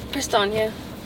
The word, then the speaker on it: Prestaina: Prestaina.